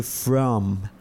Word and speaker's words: from: Digo, where are you from? Where are you from? From. Se escribe con M al final.